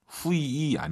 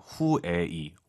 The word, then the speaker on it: 会议: 후이 아니죠. UI 사이에 에 넣으셔야죠. 후에이. 워낙 빠르게 발음하니까 잘안 들릴 뿐이죠. 후에이.